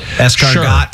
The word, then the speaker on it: escargot: So I have a buddy who, when we order escargot, he says, I'll take the escargot. – Goot, not even got. Like, I can see you saying got because that's how it's spelt. Escargot. – Sure. Yeah. – but escargot. – Goot.